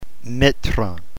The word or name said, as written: metron